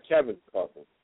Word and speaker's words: Kevin Coughlin: Kevin Coughlin.